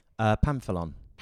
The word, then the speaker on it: Pamphilon: How is it pronounced? – Pamphilon. – Pamphilon. – Yeah. – Oh, okay. Literally as it's spelled.